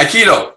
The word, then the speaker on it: aikido: Divine Aikido? – Oh, yeah, Aikido.